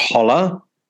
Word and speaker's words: hola: I think it's pronounced holla.